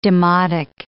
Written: demotic

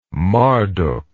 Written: marduk

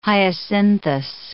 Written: Hyacinthus

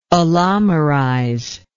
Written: allomerize

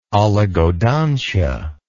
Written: oligodontia

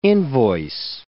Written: invoice

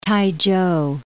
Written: T'ai-chou

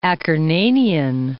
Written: Acarnanian

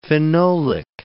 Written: phenolic

